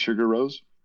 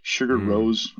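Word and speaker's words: Sigur Rós: Sugar Rose, I think that's the way you say it. It's not how you spell it.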